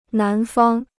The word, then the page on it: 南方: nán fāng